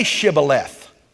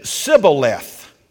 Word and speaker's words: Shibboleth: And the people of Gilead, they were able to say, and I'm doing this in English, not Hebrew, they would say, Shibboleth. But the people of Ephraim couldn't say Shibboleth. They said Sibboleth. They couldn't pronounce the H. And every time one of them said Sibboleth instead of Shibboleth, they were executed.